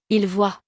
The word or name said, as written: il voit